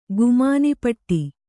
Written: ಗುಮಾನಿಪಟ್ಟಿ

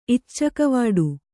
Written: ಇಚ್ಚಕವಾಡು

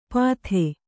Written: ಪತ್ತೆ

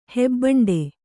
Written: ಹೆಬ್ಬಂಡೆ

